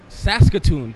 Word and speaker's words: Saskatoon: Saskatoon.